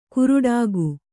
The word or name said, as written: ಕುರುಡಾಗು